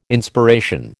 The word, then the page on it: Inspiration: American English